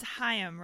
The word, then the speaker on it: Haim: Wait, remind me how to pronounce that name. – It's Haim, right? – Haim? – Was I right? – Yeah, that sounds about right.